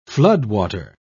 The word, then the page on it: floodwater: British English